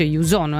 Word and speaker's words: EU-Zone: Questo è il nuovo appuntamento del lunedì e il giovedì con l'Europa, si chiama Youzone, si scrive EU-Zone, si dice Youzone,